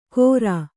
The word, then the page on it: ಕೋರಾ: Kannada